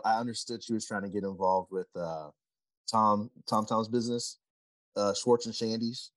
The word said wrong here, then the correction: Schwartz and Sandy's: Yeah, Schwartz and Sandy's.